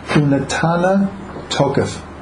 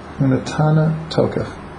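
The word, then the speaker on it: Unatana Tokef (ונתנה תוקף): Unatana Tokef.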